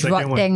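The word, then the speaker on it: Rodtang: I think it's Rod Tang, right? – Yeah, it's Rod Tang.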